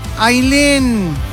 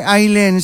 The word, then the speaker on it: Ailén: ¿Cómo se escribe, Ailén? ¿Se escribe A-Y-L-E-N? – No, mi nombre es uno de los pocos que conozco que se escriben A-I latina doble L-E-N. Se escribe Ailén y se dice Ailén.